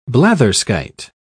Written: blatherskite

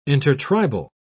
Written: intertribal

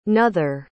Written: nother

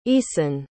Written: eason

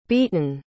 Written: beaton

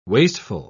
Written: wasteful

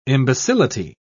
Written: imbecility

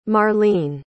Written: marlene

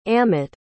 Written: amett

